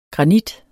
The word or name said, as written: granit